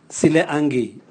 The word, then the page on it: sileagi: Raga